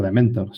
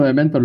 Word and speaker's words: DMNTR: No es Dementor, quítale las vocales, pero claro, es impronunciable. ¿Cómo lo pronuncias cuando alguien te pregunta cómo se llama tu newsletter? – No, yo siempre digo Dementor.